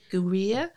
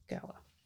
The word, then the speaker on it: Gwir: It's Gwir. Ginseng Gwir. – Yeah, but you pronounce it Gower. – Gower.